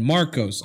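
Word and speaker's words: Markos: So anyone who voted for K-E-Y-M-O-U-S. – So they anyone who voted for Marcos, Markov, they kill them, basically blow their brains out. – You had it right the first time, and you still said Markov.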